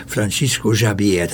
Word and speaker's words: Francisco Javier: Francisco Javier, ez lenne a helyes kiejtése a nevének.